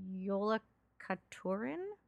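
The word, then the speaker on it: Jólakötturinn: And then there's also this asshole beast called the Yule Cat that is pronounced Yolakaturin.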